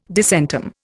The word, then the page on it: disentomb: Indian